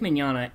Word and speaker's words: Mignogna: Vic Mignogna, a.k.a.